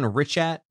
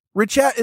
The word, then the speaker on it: Richat: Didn't we settle on Richat? – Richat is what I had saw, but then I'm reading and people just say all sorts of different things.